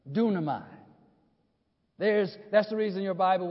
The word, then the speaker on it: dunamai: The word is dunamai, right? Phonetically spelled D-U-N-A-M-A-I.